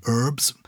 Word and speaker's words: herbs: as well as all the herbs... I'm saying that, the American pronunciation of herb, as well as all the herbs, I think they may have all the flowers named in Shakespeare.